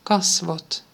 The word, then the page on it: ansikte: [ˈanˌsɪk.tɛ]